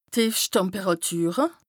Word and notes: d' Tìefschttemperàtüre: Bas-Rhin; Strasbourg

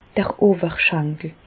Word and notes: d'r Owerschankel: Bas Rhin; Reichshoffen

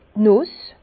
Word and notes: d' Nùss: Bas Rhin; Herrlisheim

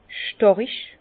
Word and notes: stàrig: Bas-Rhin; Herrlisheim

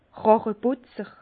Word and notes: Ràcheputzer: Bas Rhin; Herrlisheim

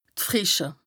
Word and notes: d' Frìsche: Bas-Rhin; Strasbourg

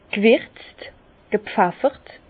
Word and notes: g' wirtz, gepfeffert: Bas-Rhin; Herrlisheim

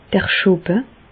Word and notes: d'r Schoppe: Bas-Rhin; Reichshoffen

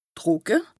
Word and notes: trùcke: Bas Rhin; Strasbourg

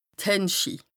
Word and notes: d' Händschi: Bas-Rhin; Strasbourg

